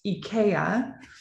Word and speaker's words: IKEA: It's called IKEA, or better pronounced IKEA. – Right. – In America, we butcher everything.